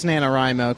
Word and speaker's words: NaNoWriMo: I think it's NaNoWriMo, because... – No, I think you're right.